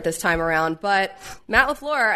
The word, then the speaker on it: LaFleur: He said it's supposed to be LaFleur. Like LaFleur.